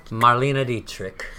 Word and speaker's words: Marlene Dietrich: Marlene Dietrich? – Marlena Dietrich.